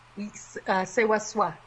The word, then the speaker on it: Ssewaswa: I'm going to slaughter the pronunciation, but Ssewaswa? – Ssewaswa. – Ssewaswa. Okay.